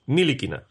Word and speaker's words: Ntilikina: De momento vamos a dejar como oficial el nombre ruandés, pronunciación Ntilikina.